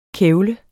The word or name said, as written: kævle